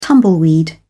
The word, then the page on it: tumbleweed: British English